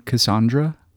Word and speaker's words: Cassandra: You know, I just found out a couple days ago she pronounced her name Cassandra.